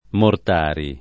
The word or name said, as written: Mortari